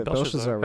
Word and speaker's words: Belshazzar: He's like, what's Belshazzar? No, what's the king and Daniel? – I think you said it right. – Which one? Belshazzar? – Yeah, Belshazzar was one of them.